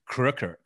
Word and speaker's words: Kröker: So the real spelling should be K-O-N-I-G with the O with the two dots. So Croker or Craker's last name should be K-R-O with the umlaut, K-E-R without the E. The E is just to symbolize that there should be an umlaut over the O. So the way you pronounce, for instance, Walter's name, the umlaut, Walter Koenig is really Koenig in German. So er is the sound of the O-E. Yeah. So it should be Kröker is what it should be, but that's very German and not anglicized.